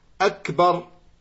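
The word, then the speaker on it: Akbar (أكبر): Il va dire Ak Ak Akbar. En réalité, il a prononcé autre mot que ce qui est légiféré dans Tekbir. Il a prononcé autre lettre que ce qui est légiféré dans le autre mot que ce qui est légiféré dans le Fatiha. Car ce qui est légiféré dans Takbir, c'est Akbar.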